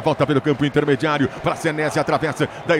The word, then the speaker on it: Senesi: Yuri Queiroga, você que é o nosso Yuripédia, a gente vai de Marco Senesi ou Senesi, a pronúncia correta? – Senesi.